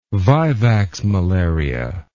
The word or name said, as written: vivax malaria